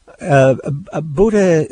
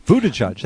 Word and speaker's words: Buttigieg: Buttigieg. – Buttigieg.